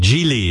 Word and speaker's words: Gigli: Geely is how you say it. It's spelled G. It's spelled G-I-G-L-I, but it's Geely.